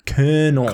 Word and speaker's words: colonel: There's no R. Colonel.